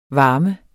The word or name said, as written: varme